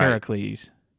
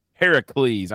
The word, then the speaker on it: Heracles: Say it right. – Heracles. – Heracles. I went, that's the most ghetto thing I've ever heard. – Heracles. I always heard pronounced Hercules.